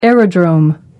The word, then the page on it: aerodrome: American English